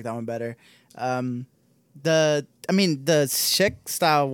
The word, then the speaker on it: Czech: Yeah, it's Czech, like checkmate.